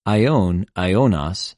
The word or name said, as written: αἰων,αἰωνος